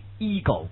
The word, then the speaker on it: ego: 下个词 ego， in America we say ego， yeah，美国人读作 ego，懂吗？